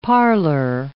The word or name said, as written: parlor